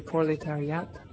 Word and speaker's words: proletariat: And we were taught that also it's a dictatory of the proletariat.